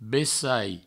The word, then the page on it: bessai: Provençal